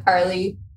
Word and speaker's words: Carli: So on Instagram, I'm just at CarlyAnna_, and the Carly is spelled C-A-R-L-I.